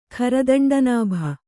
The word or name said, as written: ಖರದಂಡನಾಭ